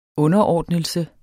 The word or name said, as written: underordnelse